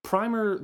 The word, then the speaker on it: primer: Primer, the word is actually supposed to be pronounced primer.